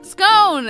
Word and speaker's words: scone: It rhymes with a phone. – Scone!